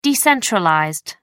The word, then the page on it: decentralized: American English